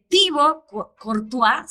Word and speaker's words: Thibaut Courtois: Y luego Tom Brady, que ha hablado de la dieta antiinflamatoria, y el caso de Thibaut Courtois,